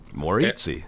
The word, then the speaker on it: Maurizi: Maurizi. – Maurizi, yes.